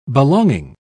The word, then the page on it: belonging: British English